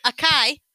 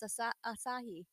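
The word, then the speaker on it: açaí: Acai berries. – Acai berries. Is that not how it's said? – No, it's asahi.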